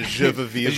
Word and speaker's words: je veux vivre: Those are the people who give us that joy, je veux vivre. Is that how you say it? – Yeah, the je veux vivre.